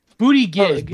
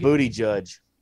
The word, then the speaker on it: Buttigieg: Booty gig. – Booty judge.